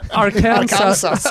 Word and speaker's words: Arkansas: Ah, fiskebåtar från Arkansas. – Ja. Jävlar vad du sliter med uttalet på den här staten. Ja, jag vet. Alltså. Arkansas. – Arkansas.